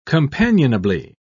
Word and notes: companionably: British English

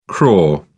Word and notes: crore: British English

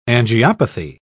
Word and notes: angiopathy: British English

